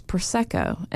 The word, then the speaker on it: Prosecco: And finally, one of these very kind people said, you're mispronouncing it. It's Prosecco.